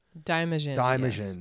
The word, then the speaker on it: Daimajin: But they did Godzilla for a bit, and he fights how do you pronounce Daimajin? – Daimajin, yes.